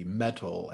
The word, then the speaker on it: Mettle: So mental health, first, it's spelled M-E-T-T-L-E, metal, as in like one's inner strength, one's inner reserve.